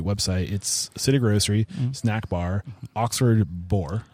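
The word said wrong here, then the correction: Bouré: Am I saying that right? Bure. Thank you.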